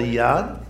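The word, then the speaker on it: yod: It's called a yad. It is pronounced with a Y sound.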